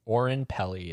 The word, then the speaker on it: Oren Peli: Oren Peli, is that how you say his name? – Oren Peli, yeah.